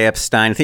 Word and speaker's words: Epstein: I say Epstein. You just think, think Einstein with an F.